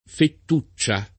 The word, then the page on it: fettuccia: lingua italiana